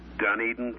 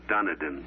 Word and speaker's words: Dunedin: Dunedin, Florida. It's on the west coast, right above Clearwater. – Dunedin, Florida. – Mm-hmm. – Jaws did not pronounce that quite correctly. – No, it usually comes out Dunedin.